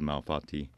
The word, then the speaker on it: malfatti: Or even just irregular shapes, which is called malfatti, if I'm pronouncing it correctly. – No, that's right, So, or which is translated to badly made pasta.